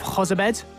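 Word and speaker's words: Jozabed: And we're going to discuss both those topics, plus we'll also examine the departure of Hozabed. Have I pronounced that all right? – Yep.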